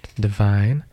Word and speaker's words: DVYN: Our highlight today is DVYN, pronounced Divine, a Toronto-based artist.